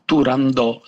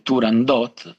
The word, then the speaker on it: Turandot: Sappiamo però da una serie di indizi che tutti nell'aprile 1926, prima esecuzione dell'opera al Teatro alla Scala, tutti pronunciavano Turandot senza la T. Quali sono questi documenti? Un'intervista tardiva a Rosa Raisa, che è la prima interprete del ruolo, all'intervistatore che si ostinava a dire Turandot,